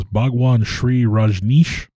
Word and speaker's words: Bhagwan Sri Rajneesh: Correct me if I'm wrong, but her role was basically a secretary to the main guy, which was Bhagwan Sri Rajneesh. – I believe that is, yes, that's the and correct pronunciation.